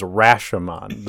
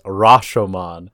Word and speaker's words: Rashomon: The American pronunciation is Rashomon. The proper pronunciation is Rashomon.